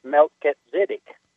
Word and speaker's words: Melchizedek: Melchizedek was a title. – Why do you say that? – Well, because Melech in the Hebrew is king, and Zadok is righteousness. It's Melchizedek is actually how it's pronounced.